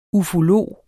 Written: ufolog

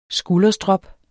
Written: skulderstrop

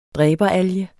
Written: dræberalge

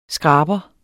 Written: skraber

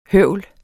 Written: høvl

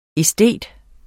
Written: æstet

